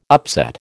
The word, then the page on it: upset: American English